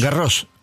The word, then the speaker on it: Garros: Garros, claro. La S final. – La S final a veces va, a veces no va.